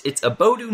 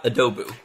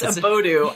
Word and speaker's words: Abodu: And I always, it's Abodu, not Adobu, right? – It's Abodu. I've had many a cover letter misspell it, which is always like a question if I should disqualify it. – Exactly. So it's Abodu, like an abode, like your humble abode.